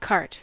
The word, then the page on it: cart: American English